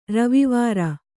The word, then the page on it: ರವಿವಾರ: kannada